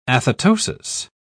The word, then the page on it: athetosis: British English